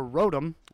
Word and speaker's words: Rotom: Or Rotom, as you call it, it's most likely because they're speaking fast. It's Rotom.